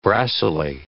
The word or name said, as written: brassily